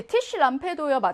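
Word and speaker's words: Tischlampe: Tisch Lampe 합성 명사니까 강세는 앞에 있습니다.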